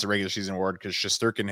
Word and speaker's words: Shesterkin: Shesterkin? – Yeah, close enough.